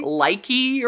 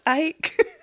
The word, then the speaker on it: Ike: Likey or something. – Yeah, Likey. I was like, I think it's Ike.